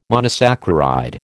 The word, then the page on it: monosaccharide: American English